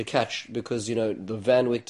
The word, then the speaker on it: Van Wyck: I was driving up the Van Wyck, or more correctly pronounced in Dutch as the Van Wyck,